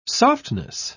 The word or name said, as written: softness